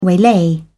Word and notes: waylay: British English

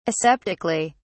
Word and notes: aseptically: British English

